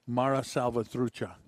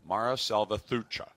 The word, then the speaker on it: Mara Salvatrucha: Mara Salvatrucha. – Mara Salvatrucha.